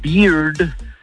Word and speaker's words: bear: Porque con, o sea, la barba se pronuncia beard, el oso se pronuncia bear, y la cerveza se pronuncia beer. Pero con R al final.